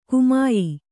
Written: ಗಮ್ಯ

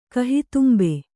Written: ಕಹಿತುಂಬೆ

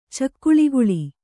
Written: ಚಕ್ಕಳಿಗುಳಿ

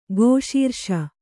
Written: ಕೇಶರಾಶಿ

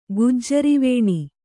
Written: ಗುಜ್ಜರಿವೇಣಿ

